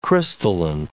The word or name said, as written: crystalline